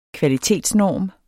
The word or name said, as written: kvalitetsnorm